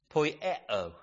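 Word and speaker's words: poieo (ποιέω): It is poieo. Almost sounds like Spanish as I said it, but I'll ask Stephen if that resembles Spanish. But it's supposed to be Greek. It's spelled P-O-I-E-O. Poieo.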